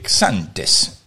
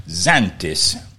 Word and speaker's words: Xanthes: Xantes o Xantes en inglés se escribe X-A-N-T-H-E-S. Recuerden que en inglés existe la costumbre que cuando una palabra empieza con X, ellos lo leen como una Z, de como Z cuando vuela una mosca por una habitación.